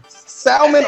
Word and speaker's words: salmon: Salmon or sal- salmon? Sal- salmon, like with the L. I do pronounce it.